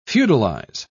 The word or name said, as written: feudalize